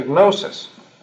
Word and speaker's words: gnosis: gnosis. Gnosis spelled with a G, gnosis, G-N-O-S-I-S, see.